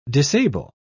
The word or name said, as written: disable